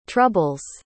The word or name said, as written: trouble's